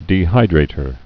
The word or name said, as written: dehydrator